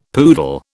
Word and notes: poodle: British English

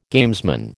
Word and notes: gamesman: American English

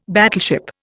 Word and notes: battleship: Indian

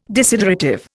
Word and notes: desiderative: Indian

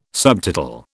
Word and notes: subtotal: British English

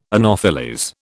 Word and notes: anopheles: British English

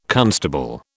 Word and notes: constable: British English

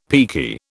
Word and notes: peaky: British English

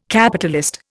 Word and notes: Capitalist: Indian

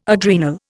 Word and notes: adrenal: Indian

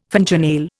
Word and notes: fingernail: Indian

